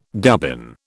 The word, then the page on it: dubbin: British English